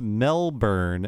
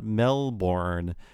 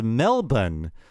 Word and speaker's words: Melbourne: And Bradley Winton says, "Gonna be that Aussie, but it's pronounced Melbourne and not Melbourne. And if you really want to be Aussie about it, it's Melbourne."